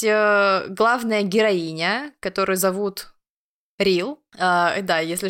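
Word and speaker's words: Re-l: ее имя так и пишется Реал. Я очень долго смеялась. – Там на самом деле Реал, но А еще выпадающее, поэтому иногда там Рил просто без А.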